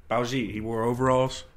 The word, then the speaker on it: Baljeet: It rhymes with Khajiit from Skyrim, you know? – Baljeet? – Yeah, what the fuck is that?